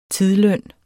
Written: tidløn

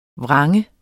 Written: vrang

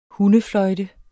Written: hundefløjte

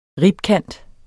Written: ribkant

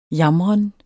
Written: jamren

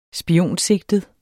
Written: spionsigtet

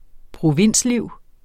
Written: provinsliv

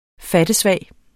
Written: fatsvag

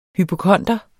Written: hypokonder

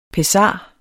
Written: pessar

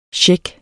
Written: CHECKENE

